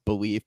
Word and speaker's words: Bleav: It's pronounced Bleav, but it's spelled B-L-E-A-V.